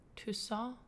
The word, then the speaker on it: Toussaint: Toussaint? – Toussaint. See, you said it right.